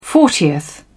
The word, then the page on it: fortieth: British English